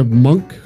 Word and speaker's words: Mounk: Monk, M-O-U-N-K. If I pronounce that right? – Monk. Just say monk.